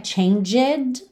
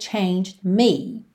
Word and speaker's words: changed: Lembra que a pronúncia não é changed, ok? A gente tem um ED aí, mas o E não é pronunciado. Então, the heartbreak changed me.